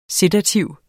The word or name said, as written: sedativ